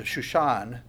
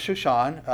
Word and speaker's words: Shushan: probably the way you pronounce this is the Shushan, according to the Shushan, a maskil of the sons of Korah, a song of love.